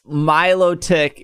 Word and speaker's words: Milotic: Because Milotic is one of the most incorrectly pronounced Pokemon. – I swear I said Milotic. Because when we were watching, I said, "Oh, melodic," and you were like, and then like 3 seconds.